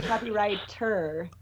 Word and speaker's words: copywriter: and she is by trade a copyright. Is that right? Did I say that right? – You said it like Dominic says it. He says the copyright all the time. Well, actually, a copywriter.